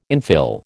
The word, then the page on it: infill: American English